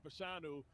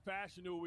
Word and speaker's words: Fashanu: Well, first of all, when you talk about it, it's funny because I've been saying Fashanu, and Fashanu, we just discovered that's how you pronounce it a couple of days ago.